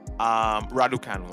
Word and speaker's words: Raducanu: But a little part of you just couldn't help but root for 18-year-old sensation Raducanu. Raducanu, let me pronounce it properly.